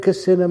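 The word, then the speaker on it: kasina: Doing casino meditations? And sometimes some people, when they hear casino meditations, I've got to pronounce it very carefully. Casino meditation, nothing to do with gambling.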